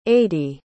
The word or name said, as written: ady